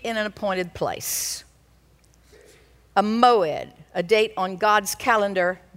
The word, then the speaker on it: moed: And those dates are called in the Hebrew, and I do think you should learn this word, moed. Moed. You can spell it M-O-E-D.